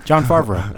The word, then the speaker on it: Favreau: John Favre? How are you saying? – John Favreau.